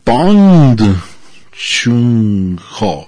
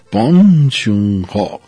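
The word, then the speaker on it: Bong Joon-ho: Entonces, él, su nombre lo pronuncia como, nosotros diríamos como es Bong, y luego J, W, Bong, Hong, Ho. En realidad es Pond Chung Ho. Pond Chung Ho.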